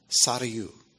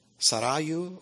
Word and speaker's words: Sarayu: And there's movement inside the word itself. So you can accent correctly. You can accent either the first or second syllable. Sarayu or Sarayu. And Sarayu. It has a rolled R in it, which I loved because I grew up overseas, right?